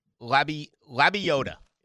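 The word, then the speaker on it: Lebioda: Leah Boda, I believe, is how you pronounce his name. Didn't we? We looked it up phonetically. – He finished tied for 15th. – Right. It's Hank. Yeah. Labiota.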